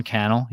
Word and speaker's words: Cannell: Sean Cannell. Yeah. He always says rhymes with YouTube channel.